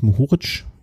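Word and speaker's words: Mohorič: Naja, und da hat sich, ähm, wie spricht man ja richtig aus? Mohoric, ja, ne? Mhm. Ja. Hat sich Mohoric äh, dann am Ende durchgesetzt äh, vom Team Merida Bahrain und hat das Ding ganz knapp von Nils Bollett.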